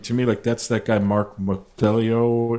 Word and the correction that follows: Mosiello: is that, am I saying his name right? – Mike Mosiello. Mosiello.